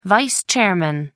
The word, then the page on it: vice-chairman: American English